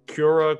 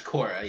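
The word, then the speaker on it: Quora: Was it Cura, Cora? – It's Cora, yeah.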